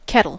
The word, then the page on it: kettle: American English